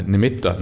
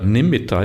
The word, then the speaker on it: nimitta: They are like creations of the mind almost, uh, uh, including the samadhi, what is often called the samadhi uh, nimitta. Nimitta is often often pronounced nimitta, but actually it's nimitta double T. I reckon.